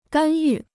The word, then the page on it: 干预: gān yù